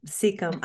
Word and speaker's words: Secombe: Secombe.